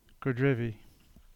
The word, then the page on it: Godrevy: English